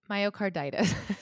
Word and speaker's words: myocarditis: Myocarditis.